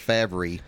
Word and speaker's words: Favre: If anybody wants to check the spelling, it's F-A-V-R-E, but when you pronounce it, the R comes before the V.